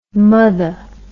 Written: motha

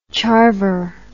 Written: charver

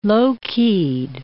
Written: low-keyed

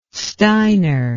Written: steiner

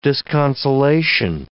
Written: disconsolation

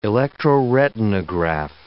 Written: electroretinograph

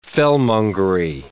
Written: fellmongery

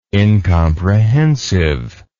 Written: incomprehensive

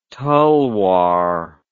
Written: tulwar

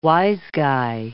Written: wise guy

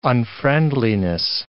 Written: unfriendliness

